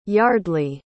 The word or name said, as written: yardley